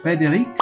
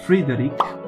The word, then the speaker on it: Frédéric: Yeah, you can pronounce it either in the French way, because he was a French... he was not French, but he went to live in France. So you can say Frédéric. – Frédéric? – In the French way. – Ah, otherwise, Frédéric. You can say you can say the Polish way, which is Frédéric. Not so different, but maybe I'm also mispronouncing.